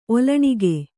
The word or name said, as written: ಒಲ್ಲಣಿಗೆ